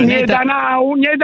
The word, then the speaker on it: Netanyahu: La pronuncia esatta com'è? – Netanyahu.